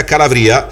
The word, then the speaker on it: Calavria: E poi c'è la pizza Calavria, la pizza che è la specialità della Calabria, giusto? Detto giusto? Detto bene? – Giusto, ah. Tutto senza glutine.